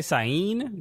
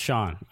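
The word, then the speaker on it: Sian: It's S-I-A-N. Is it Sion? Is it Sean? – I'm going to say Sain. – Sain? There's no E. It's S-I-A-N. – But the I can be in certain languages. – Sean. – Oh, it's Sean. That's how it's pronounced. Sean.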